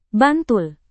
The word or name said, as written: bantul